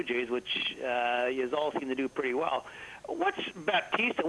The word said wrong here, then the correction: Bautista: There's no T in Bautista.